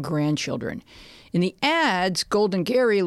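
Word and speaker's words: Gerry: He spells it Jerry, but pronounces it Gary.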